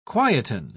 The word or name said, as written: quieten